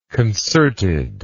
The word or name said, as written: concertedness